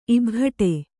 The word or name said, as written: ಇಭಘಟೆ